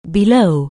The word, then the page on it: below: anglais